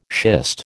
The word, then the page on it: schist: American English